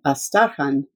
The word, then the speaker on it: Astrakhan: Plus, she was from Astrakhan, which we all pronounce the old way, Astarkhan, and she belonged to the same clan as we did.